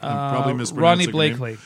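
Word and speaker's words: Ronee Blakley: And by the way, I'm gonna mispronounce her name, but the mom's played by, who is it, Ronnie Blakely or Black? – Ronnie Blakely.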